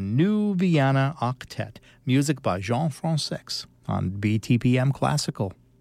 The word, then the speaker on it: Jean Françaix: Now, lots of folks prefer pronouncing his name Jean Françaix, and that became the fashionable way of pronouncing his name for obvious reasons, but he insisted it was really Jean Françaix.